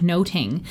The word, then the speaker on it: nothing: And so the word nothing sounded a lot more like noting.